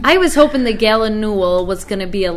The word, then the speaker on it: gallinule: And a common gallinule, which was the first spotted by Chris Chine. – How do you pronounce that again? – Gallinule.